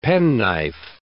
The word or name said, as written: penknife